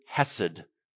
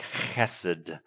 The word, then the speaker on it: chesed: In the NIV Bible, it's kindness, but it's the Hebrew word hesed, or if you really want to say it right, more like chesed.